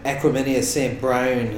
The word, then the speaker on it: Equanimeous St. Brown: But, and uh, Green Bay's uh, Equanimeous St. Brown has a high ankle sprain, uh, so he's out for four weeks.